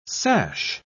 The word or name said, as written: sash